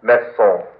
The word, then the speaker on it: maison: Maison. – Non, c'est le Z. Z. Maison. – Maison. – Maison. You see, when the S is between two vowels, you pronounce it like a Z.